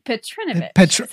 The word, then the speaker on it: Petrinovich: Petrinovich. So the emphasis is on the I.